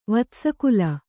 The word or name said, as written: ವತ್ಸಕುಲ